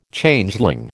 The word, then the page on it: Changeling: American English